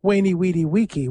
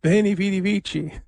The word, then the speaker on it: Veni Vidi Vici: Well, if you pronounce it with what we think was the classical pronunciation, it's Wainy Weedy Weeky, which sounds a little bit like a summer camp. Camp Wainy Weedy Weeky. No, Veni Vidi Vici. So I would go with the Italian pronunciation.